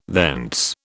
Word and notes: thence: British English

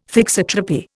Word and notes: thixotropy: Indian

